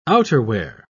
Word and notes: outerwear: British English

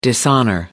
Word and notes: dishonour: American English

